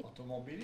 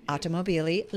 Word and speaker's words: Automobili: Am I saying it right? – Automobili. – Yes. Automobili, Lamborghini, from 05 to 2016, back in the top spot.